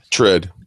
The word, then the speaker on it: TRID: Can you give a quick explanation or how do you pronounce it? TRID? – TRID. Okay.